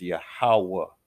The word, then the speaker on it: Yahweh: Like when you hear the Heavenly Father's name is Yahweh, that's how it's pronounced.